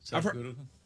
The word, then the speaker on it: Sadhguru: Am I pronouncing it right? Is it Sadhguru? – Sadhguru. – Sadhguru. – Sadhguru. Sadhguru. There you go.